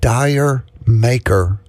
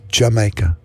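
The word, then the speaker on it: D'yer Mak'er: I always thought that was Dyer Maker because that's the way it's spelled. You know, I took phonics in school and you spell it out and you sound it out. And that's the way it's spelled, Dyer Maker. But no, it's not. It's Jamaica. Hey, Whitesnake before that, Romantics, Pat Ballard.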